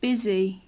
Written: busy

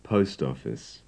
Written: post office

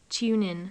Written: tune in

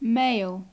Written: mail

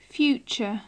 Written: future

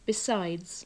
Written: besides

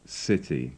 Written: city

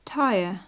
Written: tyre

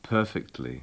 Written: perfectly

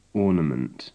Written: ornament

